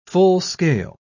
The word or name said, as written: full-scale